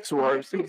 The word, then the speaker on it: Swarovski: The story was that it was a Swarovski. Is that how you say it? – Swarovski.